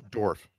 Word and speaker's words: Dorff: Dorff. – Dorff. Okay, so do you not put emphasis on the two F's at the end, like Dorff. – Dorff. – No. Dorff.